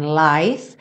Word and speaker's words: life: You say, one love, one life. Aqui sempre prestando atenção que as palavras one, love, life, todas elas terminam com E, mas essa letra E não é pronunciada.